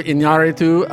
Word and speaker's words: Iñárritu: Alejandro Iñárritu, thank great.